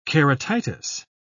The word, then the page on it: keratitis: British English